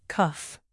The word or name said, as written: cuff